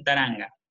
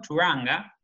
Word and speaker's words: Turanga: En la versión en inglés lo, lo pronuncia como Turanga y se ve que ellos, los latinos, interpretamos que le pusieron la A porque para mí no tenían el audio original en inglés, recibieron el guión, lo trabajaron en base a eso y pensaron, a ver, ¿cómo se pronuncia esta palabra en inglés? Turanga. Y para ellos sonaba como taranga.